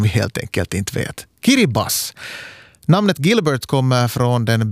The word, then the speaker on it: Kiribati: På gilbertesiska, som det heter, det lokala språket, uttalas Gilberts som Kiribas. Där TI i slutet av då, Kiribati, då man läser det sådär ordagrant, uttalas som S. Kiribas alltså.